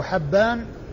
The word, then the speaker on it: حبان: فحبان هو بفتح الحاء وليس بكسرها، وهو ابن هلال وهو ثقة حديثه عند أصحاب الكتب الستة.